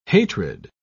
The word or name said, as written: hatred